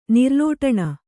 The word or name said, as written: ನಿರ್ಲೋಟಣ